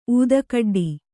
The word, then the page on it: ಊದುಗಡ್ಡಿ: ūdugaḍḍi